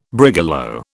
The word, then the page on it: brigalow: British English